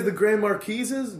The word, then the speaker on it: Grand Marquis: So you guys, the Grand Marquises? The Grand Marquis? Just pronounce the S. – Right, Just say the S.